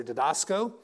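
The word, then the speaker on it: didasko (διδάσκω): Now, teaching, we get the original word for teaching in the Greek is, I'll pronounce it here, didasko, which basically means learn.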